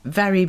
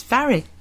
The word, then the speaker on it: Mhairi: In fact, one of the audiobooks I'm listening to now is by someone called Varry MacDonald. And it's spelt M-H-A-I-R-I. And I thought they said Barry MacDonald at first. And I thought, oh, no, I've got the wrong audiobook. But it's Varry.